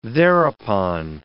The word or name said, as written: thereupon